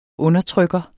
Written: undertrykker

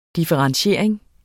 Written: differentiering

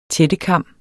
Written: tættekam

